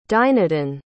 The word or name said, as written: dynadin